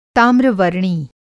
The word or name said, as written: ताम्रवर्णी